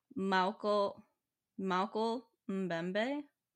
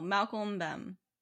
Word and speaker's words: Mokele-mbembe: Mokele-mbembe? Oh no, Mokele-mbembe. Yeah, that.